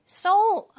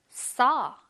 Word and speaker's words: saw: So, 아니, 얘들아, so가 아니라 그냥 saw 라고 발음해주시면 되고, last night 할 때는, t 발음은 그냥 날리세요.